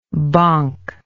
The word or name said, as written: bonker